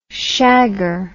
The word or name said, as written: shagger